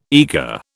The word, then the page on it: eagre: British English